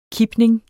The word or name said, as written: kipning